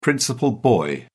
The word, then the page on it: principal boy: British English